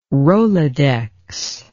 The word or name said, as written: rolodex